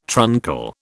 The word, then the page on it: truncal: British English